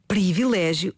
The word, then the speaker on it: privilégio: Ah, mas antes disso, Filomena, de facto a palavra escreve-se privilégio e deve pronunciar-se em contexto formal, em registro cuidado, de acordo com a norma padrão, privilégio.